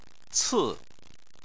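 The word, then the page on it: ci4: tone 4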